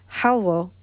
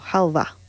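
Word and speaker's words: xalwo: And the last one I will say is called, it's written X-A-W-O, but it's pronounced halwa or halva, which means sweet.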